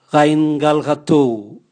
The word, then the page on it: gaingal gatou: Raga